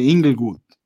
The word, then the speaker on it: Inglewood: Si alguien nos está escuchando y de los que nos van a estar escuchando están cerca del área de Inglewood, ¿lo dije bien? – Sí.